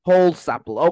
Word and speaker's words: Holsapple: So we're going to be talking to Eric Holsapple. Did I pronounce that right? – Like Holsapple. – Holsapple. Okay. Holsapple. All right.